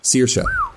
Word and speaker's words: Saoirse: That is spelled S-A-O-I-R-S-E. – I remember seeing it, and I was like, I know there's an O, I know there's an A. So S-A-O-I-R-S-E? – Saoirse. – Saoirse. – Saoirse.